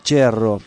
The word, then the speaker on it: Cherro: En realidad se pronunciaba Cherro, porque era italiano, venía de allá de las Italias, él no. Pero los padres, los abuelos, y se pronunciaba Cherro, porque ese cerro se escribe, pero en italiano sería Cherro, claro, bien.